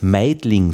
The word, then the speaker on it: Meidling: Ähm, das ist aber interessant, weil im Wort Meidling selbst äh, wird es nicht ausgesprochen.